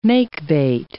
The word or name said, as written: makebate